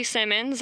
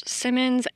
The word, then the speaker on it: Simmons: My name is Ashley Simmons. My name is spelt weird, so A-S-H-L-E-I-G-H, Simmons, S-I-M-M-O-N-S.